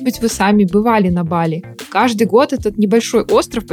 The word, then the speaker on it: Бали: Так, во-первых, действительно Бали, ударение на первый слог.